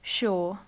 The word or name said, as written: shore